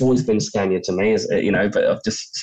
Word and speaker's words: Scania: You say Scania, but there's no R in it.